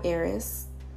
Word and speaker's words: Heiress: So listen, I've launched my brand, me and my daughter, and it's called Coco Pop Heiress at COCOPOPHEIRESS. The H is silent.